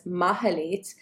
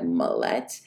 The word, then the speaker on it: Mahalit: I think of, on a practical level, the fact that I have re-owned introducing myself as Mahalit in English-speaking spaces. I went into kindergarten not speaking English. So when teachers said they couldn't pronounce my name and said Malet, I don't remember having any kind of agency or understanding of that.